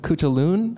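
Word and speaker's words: Cú Chulainn: Zach, you brought up as we were emailing, is it pronounced Cuchulainn? – I believe so, yeah.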